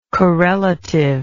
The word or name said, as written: corelative